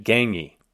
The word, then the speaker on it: Gagné: Jerome's last name is G-A-G-N-E. It's Quebecois, and I have trouble pronouncing it. Gagné.